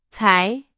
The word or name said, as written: 才